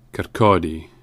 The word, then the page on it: Kirkcaldy: [kərˈkɔːdi]